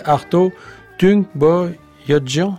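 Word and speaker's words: Arto Tunçboyacıyan: avec un musicien dont je vais avoir du mal à prononcer le nom, vous allez me corriger, Arto Tung Boyadjian. Ouais. – Vous voyez, vous y arrivez très bien.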